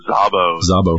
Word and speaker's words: Szabo: So let's switch to someone who is a British agent who was equally brave, didn't live nearly as long, but Violet Szabo, how do you pronounce it? – Szabo. – Yeah. Okay.